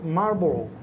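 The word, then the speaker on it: Marlborough: Marlboro.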